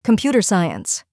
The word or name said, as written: computer science